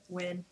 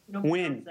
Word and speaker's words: Nguyen: How you say it? – Win. – Win. – Any of the other letters. – All silent.